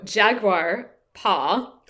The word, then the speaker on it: Jaguar Paw: I'm just saying it is really fancy the way you say that. Like Jaguar Paw. So it sounds like one full word, and I'm like Jaguar Paw.